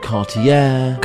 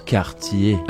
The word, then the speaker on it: Cartier: Fourth, we have Cartier, not Cartier, Cartier, or Carter. It is Cartier.